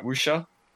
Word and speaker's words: wuxia: The cinematography is as good as a wuxia. I'm not saying that right. Wuxia? – Wuxia, yeah.